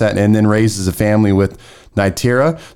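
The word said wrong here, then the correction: Neytiri: So now he is fully... – Nytiri. – Nytiri, okay.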